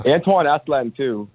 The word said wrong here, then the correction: Asselin: Jim, I don't know how to say his last. How do you say his last name? – Asselin. – Asselin.